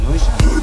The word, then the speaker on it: Noisj: No more drama on Noise Jeff. NOISJ. How are you pronouncing that? Noise Jeff.